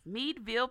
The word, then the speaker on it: Meadville: Mrs. Colemon is from Meadville. Did I say that right? – Yes. – Meadville, Pennsylvania.